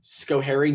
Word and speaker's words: Schoharie: You grew up in, forgive me if I get the pronunciation wrong, Schoharie, New York? – Yes, I did.